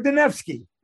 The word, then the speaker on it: Dunaevsky: Igor Dunaevsky.